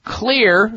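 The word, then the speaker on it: Xlear: So the next time you're in a health food store, grocery store, or a drug store, look for Clear, spelled with an X. The X is for xylitol, Clear nasal products in Walmart, CVS, Whole Foods, Vitamin Shop, Rite Aid, and other natural retailers nationwide.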